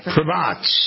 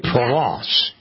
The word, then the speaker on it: Provence: It's a 1,000-acre estate in Provence, or Provence. Is that how you say it? – Provence. – Provence. – En français, Provence. Yeah.